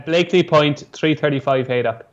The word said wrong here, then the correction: Blakeney: You've got to learn how to say it properly before we can nap it. – Blakely Point. – Blakeney. You say Blakely. – Oh, did I? – That's Blakeney. – Right.